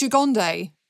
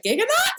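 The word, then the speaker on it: Gigandet: But also, Cam, how did you say his name? – Apparently, I believe it's Jagonde. – Okay, Jagonde makes more sense because I was always like, Giganot?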